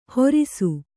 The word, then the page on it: ಹೋರಿಸು: hōrisu